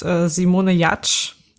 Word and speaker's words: Simone Giertz: Okay, meine Person der Woche ist Simone Jatsch. Sie wird zwar so ausgesprochen, aber sie wird anders geschrieben. Sie wird Simone Giertz geschrieben.